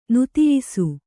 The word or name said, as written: ನುತಿಯಿಸು